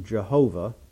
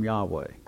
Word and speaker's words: Yahweh: How do you pronounce it? Well, older Bibles use the term Jehovah, and newer Bibles and some preachers use the term Yahweh. And the reason is what we're looking at is the what's called the Tetragrammaton, which in Hebrew is spelled Yod He Vav He, which looks like YHWH in English. Okay, which is where the folks get Yahweh.